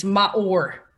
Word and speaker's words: Maor: And I butchered it. I think I butchered it. It's Maor. – Maor.